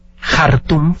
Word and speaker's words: Khartoum: Hay una película que se llama Khartoum, se escribe K-H, Khartoum, se pronuncia.